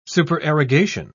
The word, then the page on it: supererogation: British English